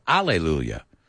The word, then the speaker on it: Hallelujah: Now, this is interesting because in English, you can pronounce the H or not. My family, of course, being an Italian American, we were Catholic, you know, my grandmother. So I always heard hallelujah or alleluia. So that's kind of, it's interesting. This is one where you can pronounce the H or not in English.